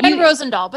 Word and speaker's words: Van Rosendaal: It's Van Rosendahl, but that's okay.